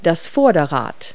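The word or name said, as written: front wheel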